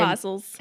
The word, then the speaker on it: apostles: And, apostles. – And apostles. Okay. Yeah. Silent T. Gotcha.